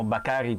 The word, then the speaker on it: Bakary: Don Antonio, abbiamo ascoltato le parole del fratello di Sacco Bakary, o Bakary come ha pronunciato lui, sì, e quindi riteniamo che sia la pronuncia più corretta.